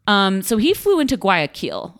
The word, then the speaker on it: Guayaquil: Guayaquil. – Guayaquil. Guayaquil.